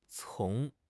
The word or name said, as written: 丛